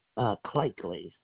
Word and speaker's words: Clakley: Clakely. C-L-A-K-L-E-Y. – C-L-A-K-L-E-Y. And spell your first name. – Deborah. D-E-B-O-R-A-A. – D-E-B-O-R-A-A.